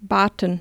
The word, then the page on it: asked: german